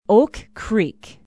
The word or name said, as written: Oak Creek